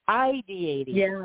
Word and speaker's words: ideating: So ideating, put the emphasis on the I. – Well, ideating.